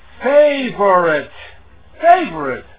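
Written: favorite

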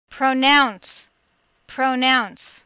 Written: pronounce

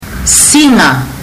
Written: siga

